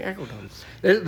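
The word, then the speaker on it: Aceldama: How do you say that first word, dimple? Acodama? I said it right. Okay, Acodama, the field of blood.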